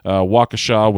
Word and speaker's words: Waukesha: But I will be returning on July 13th to Brew City Wrestling out in Waukesha, Wisconsin.